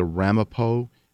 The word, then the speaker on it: Ramapo: As they have said, this film is about the, and correct me, gentlemen, if I don't say it correctly, the Ramapo Indians of New Jersey. Is that correct? – Correct. Yeah. Correct.